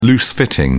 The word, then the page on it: loose-fitting: British English